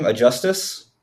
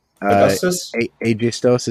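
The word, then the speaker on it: Aegisthus: Aegistus? – Aegistos is how I've been pronouncing it. – Aegistos, like Aegis is like a shield, so Aegistos, right? – That's Aegistos, probably right.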